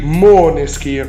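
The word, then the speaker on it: Måneskin: Ah o notte con i maneskin. Co- anzi, pronuncia giusta, scusami, pronuncia giusta i moneskin.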